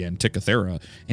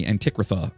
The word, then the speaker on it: Antikythera: like this is the Antikythera, and they keep calling it the Antikrytha or something like that mechanism, but I swear I looked up the pronunciation of that and I think they're saying it wrong.